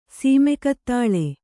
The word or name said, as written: ಸೀಮೆಕತ್ತಾಳೆ